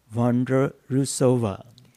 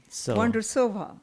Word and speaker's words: Vondrousová: but it's Markéta Vondrousová. – Vondrousová.